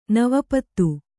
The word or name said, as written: ನವಪತ್